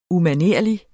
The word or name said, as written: UMANERLIG